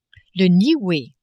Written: Nioue

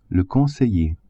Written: conseiller

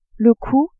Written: cou

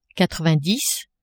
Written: quatre-vingt-dix

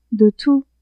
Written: de tout

